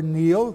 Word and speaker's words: Niall: The Celtic race deals with Niall, N-I-U-L, or Neil, pronounced today, youngest son of Phineas, Pharsus, king of Scythia.